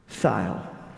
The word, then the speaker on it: Thile: Christopher Scott Thiel. That's T as in time in. H I L E, and I say it wrong sometimes.